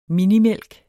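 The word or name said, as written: MINIMÆLK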